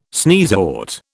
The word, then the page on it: sneezewort: British English